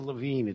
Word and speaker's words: Levine: His name is Steve Levine. It's spelled L-E-V-I-N-E.